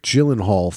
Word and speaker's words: Gyllenhaal: I'm trying to look at when Jake Gyllenhaal first did I pronounce it right that time? – Yes.